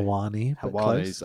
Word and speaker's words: Helwani: But in fairness, on the subject of managers, I watched Ariel Helwani's, I've pronounced that really badly. – Helwani's. Close. You always got it.